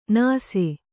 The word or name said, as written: ನಾಸಿ